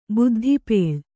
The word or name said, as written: ಬುದ್ಧಿಪೇಳ್